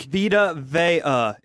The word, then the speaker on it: Vita Vea: I think Vita Vea is the official pronunciation.